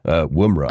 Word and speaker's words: Woomera: I'm going to go with Woomera.